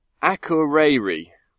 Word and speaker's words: Akureyri: And also, Nick from, and I've got to get this right because she's even posted me uh, a, a, pronunciation guide. Akureyri. Nick from Akureyri in Iceland uh,